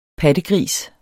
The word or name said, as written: pattegris